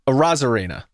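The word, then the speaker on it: Arozarena: So you took off the A, and it's not. It's a Rosarena. That's how you say it.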